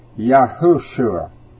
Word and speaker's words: Yahushua: and that rock was the Yahshua. I say Yahshua, it's Yahushua. Y-A-H-U-S-H-U-A. I mispronounced that U in the middle.